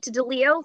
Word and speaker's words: DiLeo: So Kate DiLeo, last name will probably be in the notes. It's spelled D-I-L-E-O.